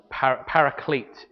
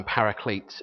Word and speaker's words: paraclete: The title of the sermon really is "By Our Side," and paraclete is the way you pronounce this term, paraclete, as it's spelt really.